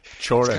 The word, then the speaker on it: Cioran: Emile Cioran, I think. – Chorin. Kind of, I think it's Chorin.